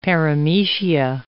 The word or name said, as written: paramecia